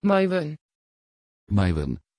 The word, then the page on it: Maiwen: Dutch